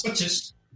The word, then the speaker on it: Hodges: No sé si lo digo bien, ¿es Hodges o Hodges? ¿Cómo es? ¿Cómo sería? ¿Hodges? – Hodges. – Que te corrija. – Vale. – Hodges. Si lo dices deprisa, da igual.